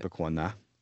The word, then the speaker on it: Tippecanoe: So, essentially gave us a free guiding trip and, you know, took us on as private access on the Tippecanoe. Is that how you say it? – Tippecanoe. Mm-hmm. That's the correct pronunciation, but you know.